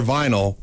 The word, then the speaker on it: vinyl: Remember, for fencing, DLR vinyl rhymes with final.